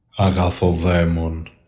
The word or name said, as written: AGATODEMON